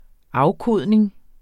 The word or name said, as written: afkodning